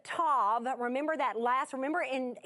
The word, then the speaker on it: tav: That word is what is T-A-W, but pronounced Tav.